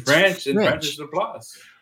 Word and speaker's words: LaPlace: That's how you say it, Laplace.